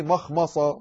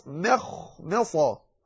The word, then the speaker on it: مخمصة: في مخمصة لا مخمصة، وهذه صعبة في البداية تحتاج إلى دربة. لماذا؟ لأنها مرققة ثم مستعلية ثم مرققة ثم مستعلية، تقول مخمصة. فمن اضطر في مخمصة غير متجانف لإثم، هكذا تنطق.